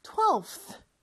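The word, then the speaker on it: twelfth: 근데 12의 V 발음이 TH를 붙였을 때는 좀 발음하기가 어려워서 비슷한 F 발음으로 바꿔서 12th 이렇게 발음한다고 했죠.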